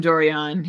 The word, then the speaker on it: Dorion: Well, didn't Dwyeron say that Carlson isn't going anywhere? – So there were mixed messages from Dorion.